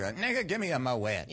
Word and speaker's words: Moët: Is it the drink is it Moët or Moët? – I believe the correct pronunciation is Moët.